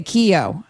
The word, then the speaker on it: Keyo: Keyo, K's like a key with an O on the end. – Okay, Keyo.